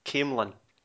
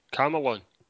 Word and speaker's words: Camelon: See, I would call it Camelon. So, Camelon. – That jumped out at me because I wanted to know how to pronounce it.